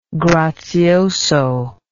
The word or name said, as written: grazioso